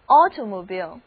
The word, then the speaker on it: automobile: 注意字母 i 在这里的读音变成了长的 e，所以叫做 automobile， automobile。